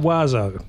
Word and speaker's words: Oizo: The next thing that we're going to listen to is Mr. Wazo. Now, that's spelled O-I-Z-O, of course. – Yeah, crazy. – Which is, and it's just a corruption of the French word for birds.